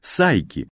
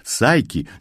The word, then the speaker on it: Psyche: P-S-Y-C-H-E. Но выговаривается оно «сайки». Да, именно «сайки», не более и не менее.